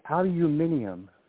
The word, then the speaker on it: aluminium: Let's just say it like the British Blog Talk woman and say aluminium.